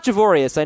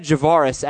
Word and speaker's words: Javorius: Yeah, it's not Javorius. I know that. I actually looked up some pronunciation guides. It's said Javaris Allen, a must-start running back going forward.